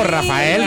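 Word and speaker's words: Raphael: Con PH. – Ok, sí. Rafael, ok. – Rafael. – Pero se pronuncia Rafael.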